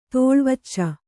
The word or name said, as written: ತೋಳ್ವಚ್ಚ